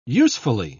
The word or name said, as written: usefully